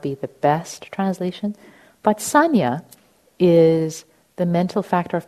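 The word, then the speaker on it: saññā: And then the concept of sanya, S-A-N-N-A, it's spelled in Pali, and perception is kind of different from how the psychological science in the West defines perception.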